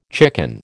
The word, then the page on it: chicken: American English